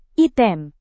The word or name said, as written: item